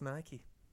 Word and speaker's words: Nike: It is pronounced Nike. – I know, I thought it was Nike. – I always thought it was Nike, because bike isn't pronounced bikey.